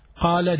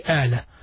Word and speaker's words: قال: And in some other colloquials, they pronounce the qaf as a. So instead of saying qala, they say ala. But the proper Arabic is qala yaqulu qawlan.